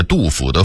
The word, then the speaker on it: 杜甫: 当然有些纯属俄化的字音我们还应该发正音，比如府和溥两个字，就是杜甫的甫和溥仪的溥这俩字儿。很多人把杜甫的甫念成溥，把溥仪的溥念成甫，这是必须要纠正的，咱不能姑息错字啊。